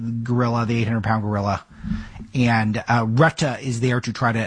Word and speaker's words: Rutte: And just remember, currently NATO Secretary General Mark Rutte, I think it's pronounced Retta, welcomed the remarks.